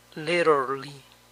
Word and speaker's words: literally: A veces se obvia la A al hablar rápido. Literally.